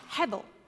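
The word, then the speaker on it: hevel (הבל): In Hebrew, the word is pronounced hevel, and it means meaningless, and sometimes it's translated as vanity.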